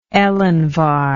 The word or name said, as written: elinvar